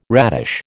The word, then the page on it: radish: American English